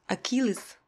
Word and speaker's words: Achilles: Единственное произношение «Achilles» немного своеобразно – «Achilles», а само выражение звучит «Achilles heel».